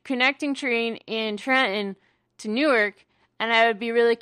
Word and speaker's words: Newark: Which is bullshit, by the way. It's pronounced Newark. – Yes. – Newark. – Listen, okay. – Sounds like a squawky bird.